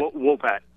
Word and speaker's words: Wopat: Is it Wopat? Did I pronounce it right? – Wopat.